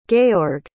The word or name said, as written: georg